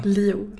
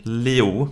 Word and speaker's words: Liu: Och sen så finns det ju då Liu, eller hur uttalar du hennes namn? – Liu. Liu. Mm. – Liu.